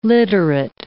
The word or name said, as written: literate